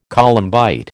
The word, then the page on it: columbite: American English